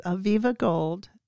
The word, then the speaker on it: Aviva Gold: It's Aviva Gold, and her book is Painting from the Source.